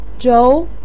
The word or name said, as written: zhou2